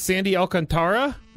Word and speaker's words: Sandy Alcantara: Now, I heard this guy's name pronounced so many different ways, but I think it's Sandy Alcantara.